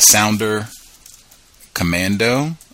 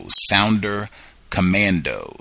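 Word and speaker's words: Sonderkommando: But the Sounder Commando, it is spelled kind of strange because I think it's spelled in German. Okay, it's S-O-N-D-E-R. K-O-M-M-A-N-D-O, Sounder Commando.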